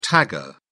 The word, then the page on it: tagger: British English